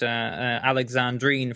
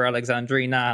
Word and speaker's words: Alexandrina: I was a bit afraid when I there was a moment at the end where I mispronounced Alexandrine for Alexandrina, and I was very glad it didn't come down to that.